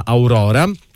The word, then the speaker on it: Aurora: Tornano con un nuovo disco che si chiama Aurora. Lo pronuncio in italiano perché è più bello.